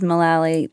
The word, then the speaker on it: Mullally: She always says Mullally, like Mullally. Sally.